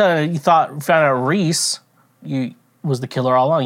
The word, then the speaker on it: Rhys: And so at the end of the first part, like, you learn that Reese, R-H-Y-S, Reese, that's how you say his name. It could have been R-E-E-S-E.